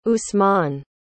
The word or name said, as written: usman